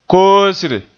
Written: koosre

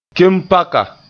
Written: kempaka

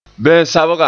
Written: ben-sabga

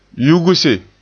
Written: yugse

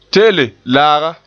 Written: te'ele

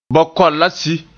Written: la

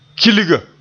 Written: kilga

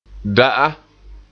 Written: da'a

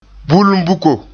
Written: bulumbuko

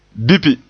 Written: bipi

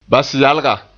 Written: basyalga

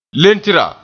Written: letra

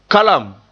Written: kalam

